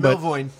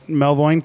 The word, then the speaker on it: Melvoin: Melvoin. – Melvoin, thank you.